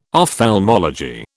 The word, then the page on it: ophthalmology: British English